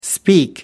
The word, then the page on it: speak: American English